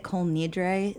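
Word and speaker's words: Kol Nidre: Well, it was the whole kind of Col, how do you say it? Col Nidre? Is that the right? – Yeah.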